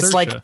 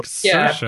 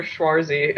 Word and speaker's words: Saoirse: Yeah, it's Saoirse. – It's like inertia. – Yeah, so, like Saoirse.